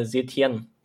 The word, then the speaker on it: Setién: Und da musst du mir jetzt einmal auf die Sprünge helfen, wie man den Herrn ausspricht, der Herr Setién. – Boah, ich hätte es genauso ausgesprochen.